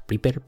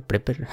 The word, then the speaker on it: Prepper: Eh, no sé cómo se pronuncia esto. Prepper, Prepper. Es P-R-E-P-P-E-R.